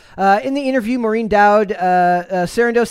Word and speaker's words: Sarandos: So this was an interview with Maureen Dowd, which is, his name is Ted Sarandos. I can never pronounce his name. I just want to say Spandos, but that's not what his name is.